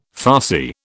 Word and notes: farcy: British English

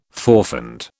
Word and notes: forfend: British English

